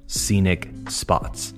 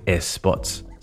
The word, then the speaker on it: scenic spots: Scenic spots. Try saying that three times quickly. Don't say es spots, say scenic spots.